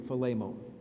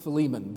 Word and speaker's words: Philemon: I learned how to pronounce it in Greek, Philemon, from my little Bible program. I've always pronounced it Philemon, but it's Philemon.